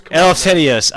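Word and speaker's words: Eleftherios: My God, you really butcher the Greek names, man. Giannis Antetokounmpo. Elefterios. – Elefterios. Oh, Elefterios. God, that's right. Elefterios is what I used to say. It's Elefterios. That's right. Good call.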